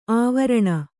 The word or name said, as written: ಅವರ್ಣಿ